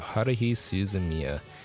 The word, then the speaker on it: Haruhi Suzumiya: It's called The Melancholy of Haruhi Suzumiya, also known as Time to Butcher the Name.